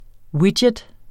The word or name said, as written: widget